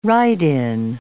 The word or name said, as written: write-in